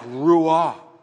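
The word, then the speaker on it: ruah (רוח): In English, we would spell it R-U-A-H, but it's supposed to be a very breathy sound, ruah.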